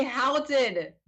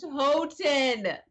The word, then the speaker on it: Houghton: So many people say Houghton. – Right. – It's pronounced Houghton. – That's correct. That is correct.